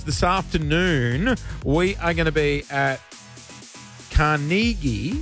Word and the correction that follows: Carnegie: No, you're butchering it again. – Damn it. – Come on, let's say, you ready? – Carnegie. We're going to be at Carnegie.